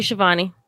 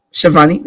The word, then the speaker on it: Schiavone: I always get his name wrong. – Tony Schiavone. – Schiavone. Thank you so much.